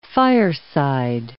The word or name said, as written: fireside